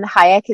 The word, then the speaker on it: Hayek: And Hayek is spelled H-A-Y-E-K.